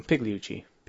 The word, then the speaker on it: Pigliucci: I was trying to figure out if you do pronounce the G, if you're just going to go Pigliucci.